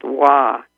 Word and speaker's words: wa: But the other interesting thing is that in the Far East, the character, the ideogram for peace is pronounced wa, W-A, and it's made up of two characters.